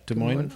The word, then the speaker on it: Des Moines: One of them I like is, I would rather spend 5 hours at the Des Moines, is that right? Des Moines? Is that how you pronounce it? – Des Moines, Iowa? – Yeah.